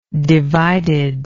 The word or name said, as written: divided